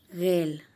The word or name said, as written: gel